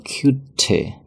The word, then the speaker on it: cutie: 这个单词本来读作 cutie，那么美式发音中呢，读作 cutie，cutie。